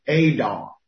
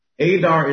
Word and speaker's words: Adar: We're in the Hebrew month of Adar. Adar is spelled A-D-A-R. A-D-A-R, and that word is Adar. And this is where we are on the Hebrew calendar month. Now, Adar, A-D-A-R, is the 12th month on the Hebrew calendar, the number 12, the 12th month on the Hebrew calendar.